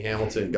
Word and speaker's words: Hamilton: Bethany Hamilton, God bless you.